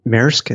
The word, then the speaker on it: Maersk: Maersk, is that the proper pronunciation? – Yes.